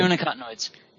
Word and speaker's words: neonicotinoids: Neonicotinoids. – Neonicotinoids. – Thank you. – Neonicotinoids.